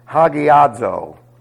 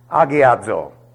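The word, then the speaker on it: agiadzo (ἁγιάζω): And you'll generally find this word looking like this, hagiadzo. See? No, it's agiadzo. That is a rough breathing, but it makes no difference in the pronunciation of the word.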